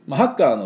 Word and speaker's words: hacker: ですね、ハッカーのスペルは HACKER です。で、この歯のところにアクセントがありますので、あと絵の真ん中のおみえーとこの音ですね、でハッカーハッカーというふうに言わなきゃいけないし、なるべくこの音をちょっと入れて、寒い時の、手に息をかけるような音、これ H の音ですからね。